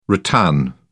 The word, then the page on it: rattan: British English